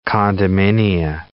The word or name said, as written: condominia